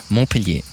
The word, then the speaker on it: Montpellier: Wir sind hier in Montpellier. Ist das richtig ausgesprochen? – Montpellier, oui.